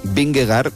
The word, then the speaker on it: Vingegaard: Es decir, el ciclista danés Vingegaard es Vingegaard, por raro que suene.